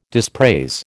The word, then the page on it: dispraise: American English